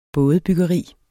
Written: bådebyggeri

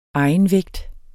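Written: EGENVÆGT

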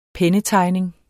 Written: pennetegning

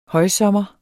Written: højsommer

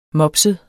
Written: mopset

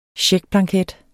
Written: checkblanket

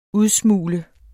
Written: udsmugle

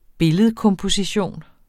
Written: billedkomposition

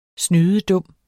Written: snydedum

